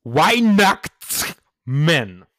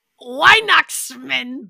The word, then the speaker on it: Weihnachtsmann: No, it's Weihnachtsmann. – Weihnachtsmann. – That's right, you got it. – Woo! – There's a T somewhere there. It took me like half an hour to get that pronunciation right.